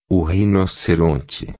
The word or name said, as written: rinoceronte